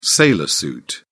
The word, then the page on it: sailor suit: British English